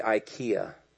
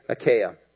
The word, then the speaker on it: Achaia: I keep wanting to say IKEA. So I have been stressing about this for 3 or 4 weeks. I've written down the pronunciation. So if I say IKEA, it's because my wife makes me go there whenever we're in Boston. So it's going to be burned in my brain.